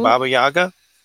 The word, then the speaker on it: Baba Yaga: Am I saying that correctly? Mm-hmm. Baba Yaga into all of this? – So in, you know, in growing up, we would hear stories about Baba Yaga. We would say Baba Yaga in English. Americans say Baba Yaga. Baba Yaga is the Russian version of it.